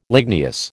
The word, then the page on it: ligneous: American English